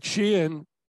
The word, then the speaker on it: Shein: So, as you know, Shelley, I wrote an article last week about this online, this online brand only called Shein, spelled S-H-E-I-N, and supposed to be pronounced as Shein.